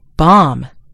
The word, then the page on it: bomb: American English